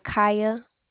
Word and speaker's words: Kya: My name is Kaya. – How do you spell it? – K-Y-A. – K-Y-A.